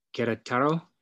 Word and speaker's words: Querétaro: Carlo was born in Querétaro.